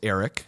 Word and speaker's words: Eric: So the first name is Eric. – Yeah. – So that's a hard C, yep. – Not a K. – Okay. – E-R-I-C. Middle name is Brandon. – Yeah. – B-R-A-N-D-O-N.